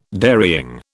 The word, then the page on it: dairying: British English